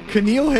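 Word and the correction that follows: N'Keal: Harry. Is that it? – Nikhil.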